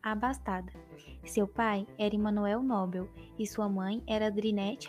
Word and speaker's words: Nobel: Bom, acho que a gente fica inclinado a falar Nobel, mas pela pronúncia, né, por, por ser em outro idioma, a pronúncia correta é Nobel.